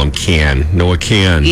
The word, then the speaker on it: Kahan: Yeah, they're spelling it K-A-H-N. Kahn. Yeah. – Okay. – Many people mistakenly pronounce it as Kahan or Kahan because of the spelling. – Yeah. – But he has confirmed that it's just Kahn.